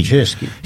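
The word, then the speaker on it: Poceski: And this article is by Mario Pachesky, I think it's pronounced, or Pachesky, however you pronounce the C. – Pachesky.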